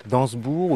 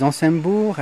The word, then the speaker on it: Ansembourg: Alors ce château, le grand château d'Ansbourg ou d'Ansembourg, on peut, utiliser les deux prononciations Oui, la famille d'Ansbourg c'est Dansbourg, mais les Français disent Ansembourg, les Luxembourgeois disent ça dépend vraiment d'où on vient et on, se comprend, donc euh, il n'y a pas de souci à ce niveau-là.